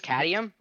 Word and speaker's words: cadmium: But then they tricked him into the, hey, let me try to pronounce it, the candium, how do you say that one? Does anybody remember? The cadmium? – I think it's cadmium.